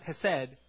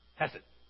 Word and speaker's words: hesed: and it's hesed. Everyone say hesed. Okay, the emphasis is on the he, okay? So hesed, not hesed, it's hesed, okay? Hesed.